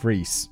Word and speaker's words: Froese: Oh, pronounced Freese.